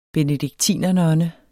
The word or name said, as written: benediktinernonne